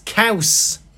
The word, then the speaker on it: caws: No, cheese in Welsh is caws. Which is like saying cows, but you got to be a bit harder with it.